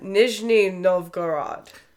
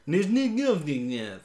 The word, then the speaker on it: Nizhny Novgorod: I'm butchering that. Nizhny Novgorod. – Nizhny Novgorod. – There you go.